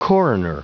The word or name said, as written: coroner